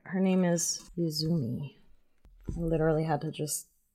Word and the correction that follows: Uzume: Uzume is the goddess of laughter.